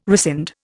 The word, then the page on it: rescind: Indian